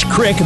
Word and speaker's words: Crick: And her name is Candace Crick, and that's spelled C-R-I-C-K, kind of like what I have in my neck this morning.